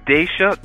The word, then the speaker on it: Daisha: Okay, so we have Daisha.